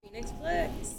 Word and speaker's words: Phoenixx Flix: Phoenixx Flix.